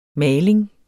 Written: maling

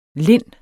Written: linde

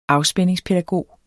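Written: afspændingspædagog

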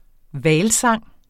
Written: hvalsang

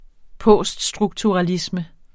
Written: poststrukturalisme

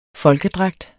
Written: FOLKEDRAGT

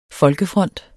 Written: folkefront

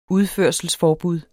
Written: udførselsforbud

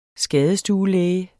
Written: SKADESTUE

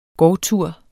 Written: gårdtur

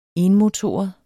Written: enmotoret

